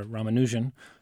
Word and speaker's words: Ramanujan: His name is Ramanujan, or Ramanujan. You can pronounce it a couple different ways.